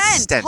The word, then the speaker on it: stent: Spelled it's spelled S-T-E-N-T. – I know. – Stent. – Like Stein. – No. Well, yeah, it's a Stein. – But without the I.